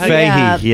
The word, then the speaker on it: Fahey: Jeff Fahey.